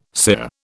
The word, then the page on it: sear: British English